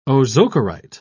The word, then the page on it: ozokerite: British English